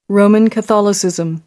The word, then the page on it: roman catholicism: American English